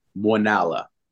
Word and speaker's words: Muenala: It is Muenala.